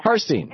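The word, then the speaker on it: Harstine: Hartstein Island, Washington. Am I saying it right? Listening to KBCS. – Harstein. – Good afternoon, Tom. Yes, Harstein Island.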